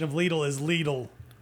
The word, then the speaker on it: Lidl: The I in Lidl is pronounced as a long E, which means it is pronounced with an EE sound and not with a short I.